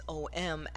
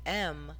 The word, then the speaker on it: Om: So when you go Om, so you would say it as Om, as it's spelled in the English language, that vibration isn't as high. But when you say it as Aum, as it is in the Sanskrit sound,